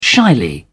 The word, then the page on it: shyly: British English